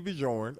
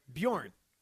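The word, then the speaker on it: Björn: I like that you pronounce the J in that word. Bjorn. – I got five of them, all right? So I say Bajorn, all right? – It's the Scandinavian word.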